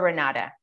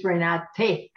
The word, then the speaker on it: Renate: So the side of yourself, the side of Jacqueline, the side of Barb, Carol, Annette, Claudia, Renee, is it Renee or Renata? Because I don't want to butcher your name. – No, my name is a German name and it's Renate.